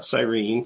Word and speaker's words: Cyrene: the proper pronunciation for that word, by the way, not Cyrene, Cyrene.